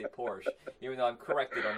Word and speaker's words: Porsche: And in the commercials and everything, it's pronounced Porsche. That is the correct way to say it.